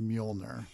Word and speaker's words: Mjolnir: So the so the name of Thor's hammer is going to be pronounced Mjolnir, but she's like, Meow Meow?